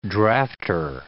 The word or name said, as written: drafter